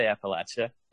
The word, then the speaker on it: Appalachia: Yeah, I mean, we say Appalachia.